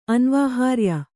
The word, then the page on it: ಅನ್ವಾಹಾರ್ಯ: Kannada